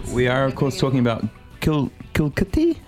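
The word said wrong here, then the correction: Kilikiti: how would you say that Kilikitty.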